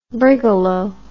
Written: brigalow

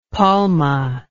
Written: palme

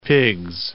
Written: Pigs, Bay of

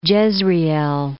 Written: Jezreel

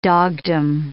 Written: dogdom

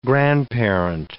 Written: grandparent

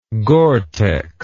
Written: Gore-Tex

